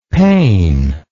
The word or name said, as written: Payne